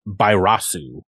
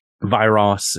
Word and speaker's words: Bairasu: I mean, um, the the Japanese name is Bairasu with like a, a, a B would, would be closer to how it's pronounced, but Gamera tai Uchu Kaiju Bairasu. I prefer uh, that. So Viras is, is kind of how I say it.